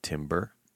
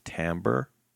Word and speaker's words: timbre: I mean, they're spelt differently, but I never realized that you could pronounce that musical sound as timbre or timber.